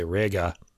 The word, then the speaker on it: Reger: The booklet says both sonically and visually on the page, this variation recalls the dense chordal agglomeration of Max Reger, or I should say Rega, for the German pronunciation.